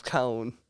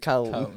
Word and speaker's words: Kohn: It's a hilarious picture, dude, of Cone. – Yeah, and it's even spelled like that. It's spelled like K-O-H-N, dude. Cone. It's spelled Cone.